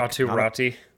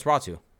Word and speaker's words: Ratu: Atu Ratu? – No, it's Ratu. – Oh, really? – Yeah. – The Y is pronounced like a U? – Yes.